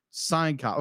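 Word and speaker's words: Syncopy: So, production was Cinecopy... Sign copy. Okay, thank you. I was trying to figure out how you pronounce that. – It's pronounced Sign Copy.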